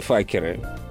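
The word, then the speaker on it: Fockers: которая в оригинале называется «Маленькие факеры». Little Fuckers. А, через букву О пишется «Fuckers», но поскольку О читается в, в, в американском английском как «А», то получается «Факеры».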